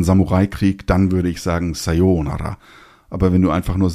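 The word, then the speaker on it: Sayonara (さよなら): Und jetzt kommen wir mal zu sowas wie einer Verabschiedung da glauben viele, sie wissen das und sagen, das ist doch Sayonara. Und da muss man leider sagen, erstens ist es Sayonara und zweitens wird es nicht nur anders ausgesprochen, sondern bedeutet etwas ganz anderes.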